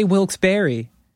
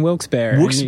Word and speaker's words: Wilkes-Barre: But there's I think three possible pronunciations. There's Wilkes-Barre, Wilkes-Barre. – Yeah. – Which is, according to the information that I received, that's the proper pronunciation. It's two French guys' last names.